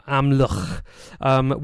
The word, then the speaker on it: Amlwch: But he was born and lived in Amlitch. I'm not sure I've pronounced that correctly. – I think it looks like Amla. – It totally doesn't fucking look like that, but it is Welsh. So, yeah, Amlwch, which is on the north coast of Anglesey.